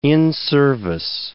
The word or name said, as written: in-service